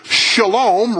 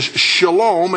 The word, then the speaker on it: shalom: It is the word shalom, right to left. It's Hebrew, the SH sound, the short A sound, the L in Hebrew, the long O sound, and finally the M. It is shalom, and shalom means completeness, wholeness, health, peace, welfare, safety, soundness, tranquility, prosperity, perfectness, fullness, rest, harmony, the absence of agitation or discord.